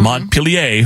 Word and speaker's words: Montpelier: Montpelier, Vermont is how you say it.